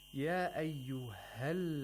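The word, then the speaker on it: يَا أَيُّهَا: Why is it that this aliful mad is not pronounced here? It's ya ayyuha. – Yeah, because actually there's a hamzatul wasl after this. – Exactly. Whenever you have a long vowel followed by hamzatul wasl, you no longer should pronounce that long vowel.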